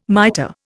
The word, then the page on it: miter: Indian